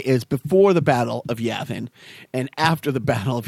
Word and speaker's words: Yavin: Yavin.